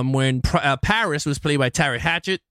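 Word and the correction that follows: Teri Hatcher: Did I say her name right? It was a Terry Hatcher. – Terry Hatcher. Yeah.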